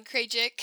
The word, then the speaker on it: Krajic: Krajic.